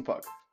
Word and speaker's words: Pugh: How about 32-year-old former Giants offensive lineman it's Justin Pugh. A lot of people pronounce it Pugh.